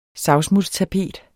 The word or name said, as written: savsmuldstapet